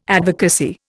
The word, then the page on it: advocacy: Indian